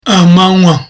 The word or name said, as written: amanywa